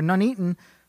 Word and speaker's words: Nuneaton: The couple were shot dead in Newton before Williams, or Nuneaton, that's how it's spelled.